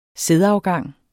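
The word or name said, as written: sædafgang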